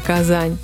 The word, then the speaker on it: казан: Так третьей столицей официально стала Казань. На татарском языке это слово означает «котел» и произносится без мягкого знака «казан».